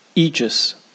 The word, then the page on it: aegis: British English